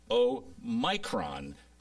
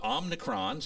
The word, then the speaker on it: Omicron: But Omicron, so it's Omni and small, Omicron, Micron, Omicron, and then Omega. So there you have them right there. – You have the long O and the short O.